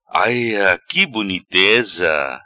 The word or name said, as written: Aia qui boniteza